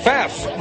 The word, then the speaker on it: Pfaff: Pfaff, the P is silent, but my God, the machine isn't.